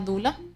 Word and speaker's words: Doula: ¿Sí se pronuncia bueno, Sí, se, pronuncia Dula.